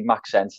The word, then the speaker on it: Maxence: But yeah, that's pronounced, for anyone to know, it's actually Maxence, and then it's spelled Lacroix.